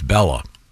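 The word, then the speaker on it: Béla: He says in Hungarian, the A-Y has to be, and then he's got accent, A-G-U over an E. – Well, Bela Lugosi is E-L-A, so, though. – And he says it's Bella. It's like Bella, not he's got it phonetically.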